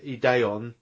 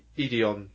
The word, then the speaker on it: Ideon: So it's pronounced, so the opening credits and the eye catch say Ideon, but if you listen to the actual characters as they say it, they say Ideon.